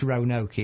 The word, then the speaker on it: Roanoke: R O A N O K E. That's Roanoke, isn't it?